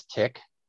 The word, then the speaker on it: Tik: Well, my first name is Tick, and it's spelled T-I-K, and it's unlike the blood-sucking insect.